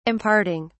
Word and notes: imparting: British English

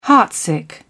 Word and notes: heartsick: British English

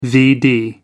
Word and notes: vd: American English

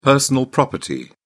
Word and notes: personal property: British English

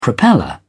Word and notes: propeller: British English

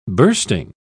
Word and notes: bursting: British English

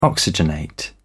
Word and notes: oxygenate: British English

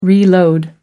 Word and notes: reload: American English

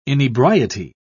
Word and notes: inebriety: British English

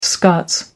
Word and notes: scots: American English

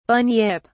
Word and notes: bunyip: British English

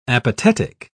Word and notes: apatetic: British English

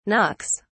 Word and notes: knocks: British English

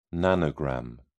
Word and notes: nanogram: British English

